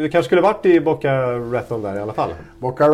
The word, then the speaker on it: Boca Raton: Boca Raton. Raton. Tänk på batong. Batong.